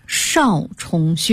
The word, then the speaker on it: 少冲: 第三个是最后末端的穴位少冲穴，这个冲啊是两点水加一个中国的冲，冲锋陷阵的冲啊，少呢就是多音字啊，多少的少。